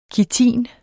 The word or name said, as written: kitin